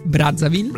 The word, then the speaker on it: Brazzaville: Brazzaville.